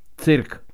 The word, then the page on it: CIIRC: Czech